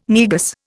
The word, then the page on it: Negus: Indian